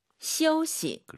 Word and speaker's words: 休息: 쉬다. 아이유 사이에 역시 오 넣어서 시 어우예요. – 休息. – 그렇죠. 시우시 슈시 하지 마시고요. 시우시.